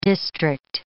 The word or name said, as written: district